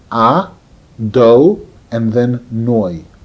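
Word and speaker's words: A, Do, and then Noi (אדני): Aleph Dalet Nun Yud, which is pronounced A, Do, and then Noi.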